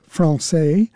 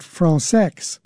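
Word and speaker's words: Françaix: He spells it F-R-A-N-C-A-I-X. It is commonly pronounced Francais, but the composer himself and his close circle of friends pronounced it Francais.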